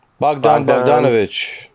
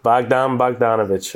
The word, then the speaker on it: Bogdan Bogdanovic: Bogdan Bogdanovic. – Bogdan Bogdanovic, okay.